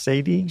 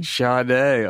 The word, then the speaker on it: Sade: Is it pronounced Sadie? – Sade. Oh, my gosh.